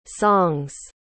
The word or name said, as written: songs